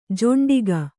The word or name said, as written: ಜೊಂಡಿಗ